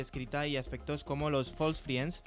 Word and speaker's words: false friends: Tampoco lo pronuncias tú muy allá. – False friends, lo he dicho perfecto, para una vez que lo digo bien.